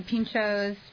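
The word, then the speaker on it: pintxos: Well, especially with the food at Estadio, like the pinchos, right? – Exactly. – Did I say that correctly? – No, definitely. – Okay, good, thank God.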